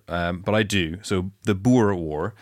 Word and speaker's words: Boer: I called it the Boer War. – Okay. – Right. – And it's actually pronounced Boer.